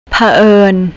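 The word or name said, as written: เผอิญ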